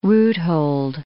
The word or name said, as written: roothold